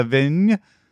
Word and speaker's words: Vigne: It's a Antinori Pian della Vigne. How do you say V-I-G-N? – Vigne.